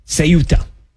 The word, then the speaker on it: Ceuta: Okay, I got to say it right. I've been saying Quetta, I've been saying Ceuta. Ceuta, I apologize.